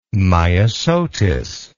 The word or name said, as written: myosotis